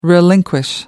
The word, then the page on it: relinquish: American English